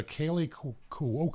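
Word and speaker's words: Kaley Cuoco: This is a Kaylee Cuoco, got her name right.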